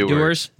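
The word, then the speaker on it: Dewar's: Dewars.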